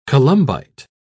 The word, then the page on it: columbite: British English